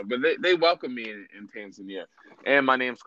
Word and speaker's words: Tanzania: And, like, so when I was in the mainland, Tanzania, well, it's pronounced Tanzania. That's okay. – They corrected me and I was there. That's how you really pronounce it.